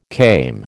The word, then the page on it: came: American English